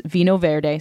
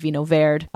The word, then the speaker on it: Vinho Verde: So is, uh, these are wines from uh, the northern part of Portugal, and the wine name itself is Vinho Verde. Some pr- people pronounce it Vino Verde, uh, and there are white versions and rosé versions, and also red versions.